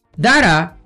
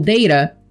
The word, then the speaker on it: data: Data, ou data, é uma palavra de dupla pronúncia, que parece muito com a nossa data, né?